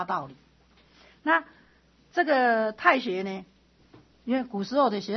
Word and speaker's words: 大学: 然后接下来说做大学，这个应该念成太学了，不是大学，太行，太行了。